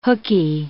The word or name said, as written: hooky